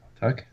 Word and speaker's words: Tuch: And excuse me if I'm pronouncing the name wrong, Alex Tuch. Tuch, yeah, that's right.